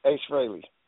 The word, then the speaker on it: Frehley: Ace Frehley.